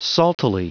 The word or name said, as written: saltily